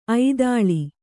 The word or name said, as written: ಐದಾಳಿ